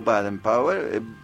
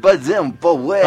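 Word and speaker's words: Baden Powell: O Baden Power, Bossa and Son Beatles. Brasileños, no lo pronuncio tan inglés. – Bueno, Baden Power.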